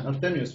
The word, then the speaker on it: Artemio: Yeah, Artemio's fine.